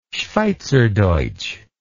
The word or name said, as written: schweizerdeutsch